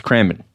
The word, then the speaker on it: Cranbourne: You don't pronounce the Bourne. – Don't you? – Is it Cranbourne? It's not Matt Damon. – Is it Cranbourne? – It's Cranbourne.